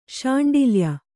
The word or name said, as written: ಶಾಂಡಿಲ್ಯ